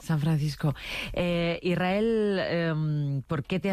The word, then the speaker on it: Israel: Y la S con la R es difícil de pronunciar. La gente dice Israel. Es sin verdad. Que suene la S, Israel, sí, es difícil pronunciarse.